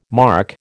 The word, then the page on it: marc: American English